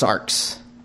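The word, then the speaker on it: sarx (σάρξ): There's a word he used there that's pronounced sarx.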